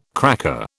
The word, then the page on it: cracker: British English